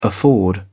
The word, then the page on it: afford: English; British English